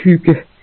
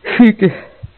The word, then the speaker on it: hygge: Where I come from, in my little part of the world, a big part of our culture is a thing called hygge.